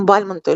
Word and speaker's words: Бальмонт: Бальмонтом-то он стал, когда во Францию уехал. Можно сказать, что взял себе псевдоним именно вот такой, на французский манер. А вот родственники, которые остались в Шуе, называют себя именно Бальмонтами. Поэтому мы привыкли именно так делать ударение на его фамилию.